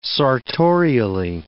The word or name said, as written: sartorially